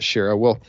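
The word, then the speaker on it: Shaara: There's a really good book where they talk about this called To the Last Man by Jeff Shara, or Shara. He pronounces it Shara.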